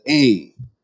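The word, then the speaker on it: aim (אם): The biblical word translated for mother in the old Hebrew, and it is pronounced in our English word as aim.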